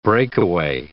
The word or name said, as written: breakaway